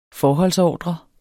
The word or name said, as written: FORHOLDSORDRER